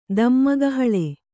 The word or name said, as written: ದಮ್ಮಗಹಳೆ